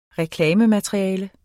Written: reklamemateriale

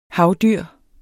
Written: HAVDYRET

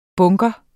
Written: bunker

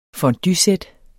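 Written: FONDUESÆT

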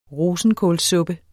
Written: rosenkålssuppe

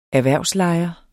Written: erhvervslejer

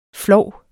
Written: flov

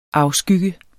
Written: afskygge

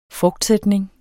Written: frugtsætning

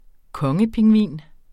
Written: kongepingvin

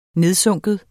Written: nedsunken

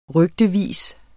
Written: rygtevis

